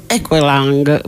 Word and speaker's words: Aqualung: Jethro Tull, allora la pronuncia si capiva è Equalang, ma io non ce la posso fare.